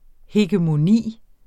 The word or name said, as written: hegemoni